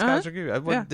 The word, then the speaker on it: Kajagoogoo: Is it Kajagoogoo? It's Kajagoogoo. Did I say it wrong? Okay, Kajagoogoo.